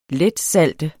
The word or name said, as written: letsalte